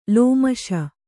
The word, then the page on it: ಲೋಮಶ: lōmaśa